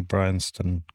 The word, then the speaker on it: Bryanston: And uh, congratulations on pronouncing Bryanston correctly. Most, most, particularly Americans, they stumble over that one.